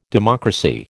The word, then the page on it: democracy: American English